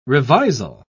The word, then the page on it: revisal: British English